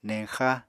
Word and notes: neñxa: Apatani

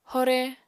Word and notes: hore: apatani

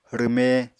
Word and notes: rwme: Apatani

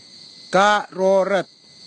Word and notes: karorut: Kwamera

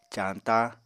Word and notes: cañta: Apatani

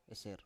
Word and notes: eser: Biak

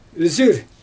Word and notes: yzyr: Xyzyl

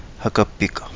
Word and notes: Hagabiga: Kapingamarangi

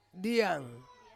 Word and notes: diang: Ansus

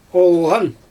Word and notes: oolGan: Xyzyl

